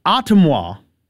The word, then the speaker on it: Ottumwa: Now, as I mentioned, I want to take a trip to Ottumwa is how to pronounce it, I believe.